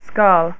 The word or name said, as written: Skarl